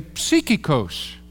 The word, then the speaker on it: psychikos: So if I pronounce it for you, we would say psychikos.